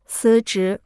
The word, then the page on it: 辞职: cí zhí